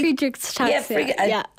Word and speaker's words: Friedreich's ataxia: Now we are just chatting here, and is living with a rare disease called Friedreich. Am I pronouncing it correctly? – Friedreich's ataxia.